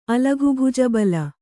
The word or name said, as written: ಅಲಘುಭುಜಬಲ